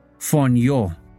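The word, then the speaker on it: phonio (φονεύω): The word here in the Greek is G5407 in the Strong's Concordance, and it's pronounced phonio, and it means murder.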